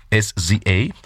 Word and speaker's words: SZA: Em, SZA, así se pronuncia, SZA, un nuevo sencillo titulado Special.